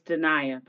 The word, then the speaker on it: Dania: It's Dania, by the way. Dania Jaxn. – Dania, oh my God.